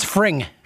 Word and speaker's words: Fring: And how did you say his name? Freed? – I think it's Fring.